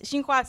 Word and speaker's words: Xinhua: First of all, you know, they're like, the pronunciation is different, like Jinghua. I was like, well, it's Xinhua, so debate pronunciation.